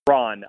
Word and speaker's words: Strawn: It's much Strawn, not Strahan, like the defensive end, which is not it's not phonetically obvious there that it's pronounced Strawn.